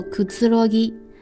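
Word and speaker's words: 寛ぎ: 那么亚斯拉吉也让我想到了另外一个单词，叫做库兹罗吉。库兹罗吉，大家有没有听过这个单词呢？它的汉字写作宽松的宽的繁体字加一个吉，念作库兹罗吉。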